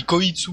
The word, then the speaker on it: Koitsu: donc avec le personnage Kotsu, j'arrive pas à le prononcer mais c'est pas grave. – Euh, oui, Koitsu voilà. Comme le Koit.